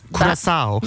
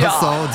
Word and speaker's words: Curaçao: Curaçao. – Nasıl okunuyor bu ya? – Curaçao diyebilir.